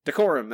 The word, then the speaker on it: Decorum: Our last book for the episode is Decorum. Decorum. Decorum. That's how you say that word.